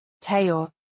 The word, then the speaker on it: Theo: He pronounces it the French way. Theo.